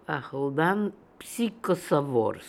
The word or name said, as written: Akhaldanpsychosovors